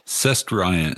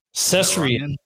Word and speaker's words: Cestrian: How would you pronounce where you're from and what you're saying you are? I, I, I want to learn this evening. I, I want to see if he mm-hmm. breaks. Okay. – Cestrian. Cestrian? – Cestrian. – Cestrian. Rian. Cestrian.